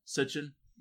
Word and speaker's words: Sitchin: Yeah, is that how you think is is that how the name's pronounced? Sitchin. Uh, yeah. I have a hard time looking at when I see a name that I can't pronounce. I have a hard time saying it. – So Sitchin.